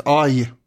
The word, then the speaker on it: Eje: Man kan säkert uttala det här på något annat sätt. Aj, Men i många texter så står det Eje, och då väljer jag att säga det, för att det låter roligare.